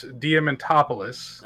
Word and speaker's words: Diamantopoulos: His name is Chris Diamantopoulos. – I think I was saying it right. – I don't believe you were. – I think Chris's pronunciation was correct. – It's Greek. It's just like Joe Manganiello.